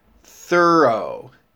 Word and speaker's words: thorough: The first syllable of the word is thr, as in Thursday, and the second syllable is O, thorough. When you say the word O, remember that it is a long O, like row or window.